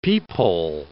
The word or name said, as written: peephole